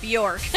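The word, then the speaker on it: Björk: It rhymes with dork. – Bjork.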